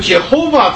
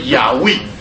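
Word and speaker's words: Yahweh: 那有的英文里面这些读经的人告诉我们说呢，哈、啊，这个 Jehovah 这个字呢。按理讲是这个发音是一个错误的发音啊，因为按照希伯来文呢，它这个字啊，这个直接转移过来的话，应该叫做亚威，亚威啊，这个耶和华这个名字呢是一个错误的一个发音啊，那么它应该叫做亚威啊，那我们晓得这是神在圣经里面亲自所启示出来的他的名啊。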